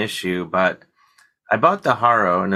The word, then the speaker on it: Haro: So I bought a Haro. Am I saying that right? – Oh yeah, yeah.